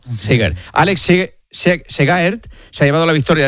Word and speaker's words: Segaert: Y en ciclismo, el belga Alex Segaert, ¿lo he dicho bien? – Segaert. – Sí.